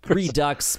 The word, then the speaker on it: Redux: Is that really how you pronounce that word, Redo? – Yes. Well, I thought it was Redux. – It's Redux, but yeah.